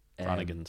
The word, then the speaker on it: Groningen: Yeah, over in Groningen. – Groningen, sorry. – It's cool. I pronounced it wrong until I got there. I was corrected by a Dutch person, which is infinitely more embarrassing.